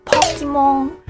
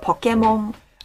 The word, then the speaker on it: Pokemon: However, non-Japanese people pronounce it something like Pokemon or Pokemon. No. It's Pokemon.